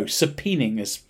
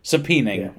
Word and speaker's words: subpoenaing: First of all, before I carry on, did you know subpoenaing is spelt like that? – Is that how you say it, even though it's spelt like... – Subpoenaing. I assume it is pronounced. – Subpoenaing. – But if you see the word S-U-B-P-O-E-N. No, that's A-I-N-G.